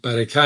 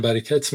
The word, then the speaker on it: برکت: یعنی از دو راه میتونی بفهمی حتی کلمه عربی در واقع از طریق فارسی وارد اون زبون شده، که اینکه تلفظش تلفظ فارسیشه نه عربیش، مثل همون که گفتی برکه نمیگن، برکت میگن، و یکم این که معانیش معنی در واقع ایرانیشه نه معنی عربیش.